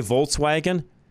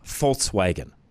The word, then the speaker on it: Volkswagen: We all say Volkswagen. It's actually supposed to be pronounced with an F, Volkswagen. In German, all the V's are F's.